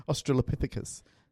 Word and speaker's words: Australopithecus: Australopithecus. – Oh, well done. How many times did that take you to actually learn that word? – Well, no, you just read it. – Yeah, I can't Australopithecus.